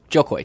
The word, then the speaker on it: Koy: But it's the opposite. It's Joe Koy. Joe Koy.